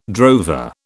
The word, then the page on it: drover: British English